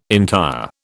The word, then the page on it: entire: British English